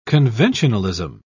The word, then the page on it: conventionalism: British English